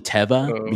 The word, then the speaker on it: Teva: Teva and Teva. Like it's actually Teva because that's what the company says is Teva.